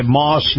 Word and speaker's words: Maus: Moss Nissan. Moss is spelled M-A-U-S.